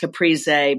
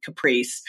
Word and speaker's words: Caprese: I grow tomatoes and I'm not sure if it's, I think if you're Italian, it's pronounced Caprese, but if you're just plain old Heinz 57 American like me, it's Caprese. But it's C-A-P-R-E-S-E.